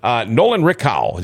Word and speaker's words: Nolan Rickow: Nolan Rickow, is that his name? – Yes, did I pronounce that right? – Yes, yes. – What a great kid, the way it sounds.